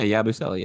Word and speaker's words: Yabusele: Gershon Yabusele. Is that how you say it? – Oh, yeah. Yabusele, yeah.